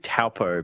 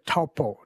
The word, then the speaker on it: Taupo: And I apologize if I butcher the pronunciation here, but I was saying that, I said Taupo, but it's not Taupo. – She would say Taupo.